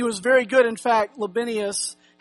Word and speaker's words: Libanius: I don't really know these words. And like Mark says, I wasn't there, so I don't know how they pronounce their names. But for us today, it's Libanius.